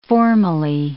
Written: formally

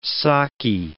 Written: saki

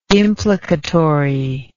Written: implicatory

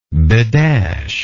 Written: bedash

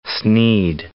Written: Snead

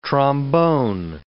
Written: trombone

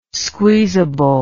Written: squeezable

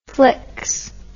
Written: flix

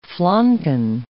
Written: flanken

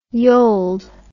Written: yold

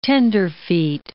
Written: tenderfeet

